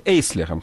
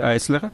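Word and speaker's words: Eisler: Причем в мое время в Советском Союзе его именовали Эйслером, хотя, конечно, немецкое правильное произношение Айслер, но в новейших справочниках опять он именуется Айслером, так что я буду его называть Айслером.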